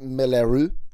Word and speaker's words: Moulin Rouge: Is that actually pronounced Melaru? Yes.